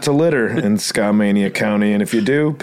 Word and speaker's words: Skamania: am I pronouncing this correctly, Skamania County, Washington? It's Skamania.